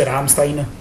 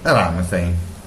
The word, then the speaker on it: Ramstein: È scritto Ramstein. – Sì. – Si legge Ramstein. – Ramstein.